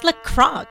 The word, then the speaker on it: LaCroix: It's not LaCroix. It's pronounced LaCroix. – You idiots. It's LaCroix. The X is silent.